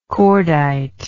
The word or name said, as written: cordite